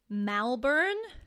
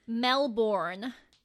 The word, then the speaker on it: Melbourne: But I find when Americans say Melbourne, they always either pronounce it Malbourne. Or they draw out the R, because, like, obviously American accents have that very hard R compared to us. – Yeah. – It's like Melbourne.